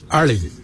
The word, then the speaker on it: ali: In our ancient language, we say early. It's spelled A-L-I.